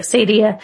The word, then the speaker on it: acedia: People have pronounced this different ways. I'm going to say Acadia.